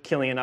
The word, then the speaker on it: Chilion: Now I look Killian up, that's how you pronounce it, so I had to look it up.